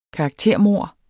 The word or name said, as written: karaktermord